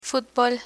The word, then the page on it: fútbol: Spanish